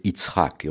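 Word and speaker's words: Yitzhak (יצחק): En réalité, si je le prononce en hébreu, c'est Yitzhak, et on se souvient encore euh, d'Istrak Rabin.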